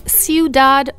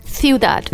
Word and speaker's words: Ciudad: Well, it's Ciudad or Ciudad. – You can say it either way.